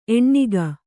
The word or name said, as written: ಎಣ್ಣಿಗ